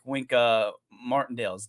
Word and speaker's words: Wink Martindale: And I mean, they're probably sitting there staying up late at night like Wink Martindale's.